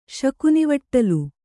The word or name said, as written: ಶಕುನಿವಟ್ಟಲು